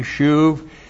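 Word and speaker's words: shuv (שוב): First of all, shuv. S-H long U-B, shuv.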